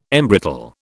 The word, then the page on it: embrittle: British English